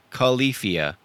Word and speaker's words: Califia: Califia is the name. Caluhfee. Oh, no, wait. Then, yeah, Califia. There you go.